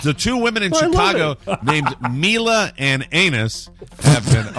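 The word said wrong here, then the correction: Anaïs: I think I mispronounced that. It's A-N-A-I-S. How would one pronounce that word? – Anise, I believe. – Anise, I'm terribly sorry.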